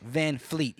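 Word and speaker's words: VanVleet: Is Fred Van Freak? Did I even say his name right? – No, that's 3, bro. It's Fred Van Fleet.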